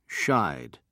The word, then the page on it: shied: British English